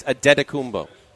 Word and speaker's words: Antetokounmpo: It's close. You're overdoing the T's. – No, I'm not. That's how you say it. – It's Antetokounmpo. – You sound like you're saying a D. – You're doing like a machine gun.